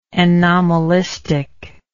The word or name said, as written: anomalistic